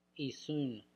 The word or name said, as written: Isoun